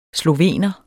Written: slovener

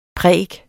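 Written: PRÆKET

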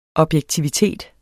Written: objektivitet

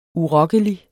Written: urokkelig

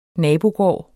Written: nabogård

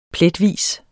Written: pletvis

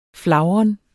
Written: flagren

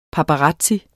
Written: paparazzo

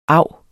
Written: av

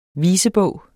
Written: visebog

